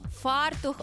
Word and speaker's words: фартух: Ну і фартух чи фартух, наголос на «а», фартух обов'язково має бути.